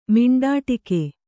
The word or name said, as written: ಮಿಂಡಾಟಿಕೆ